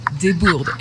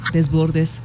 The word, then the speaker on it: Desbordes: Mi nombre es Lea Desbordes en francés, pero también que se puede pronunciar Desbordes en el español.